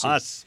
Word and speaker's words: Haas: Is that the way you say his name? Is it Haas? – Haas. – Haas. – There was a famous catcher, a very good catcher named Haas way back when. – You got it.